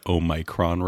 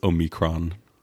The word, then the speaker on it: Omicron: Yeah, you're listening to the Omicron report. – Is that how you pronounce it? – No, I think it's Omicron.